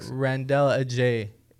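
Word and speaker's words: Randell Adjei: Randell Ajay, the poet laureate of Ontario, I believe that's how you say it.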